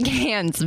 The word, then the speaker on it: Cairns: They all pronounce it differently there. – No, they don't. They pronounce it Cairns, but Americans do what they want with it. – But it's got an R in there somewhere. – Yeah, it's like C-A-I...